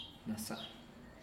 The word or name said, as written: nasa'a